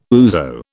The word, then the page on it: ouzo: British English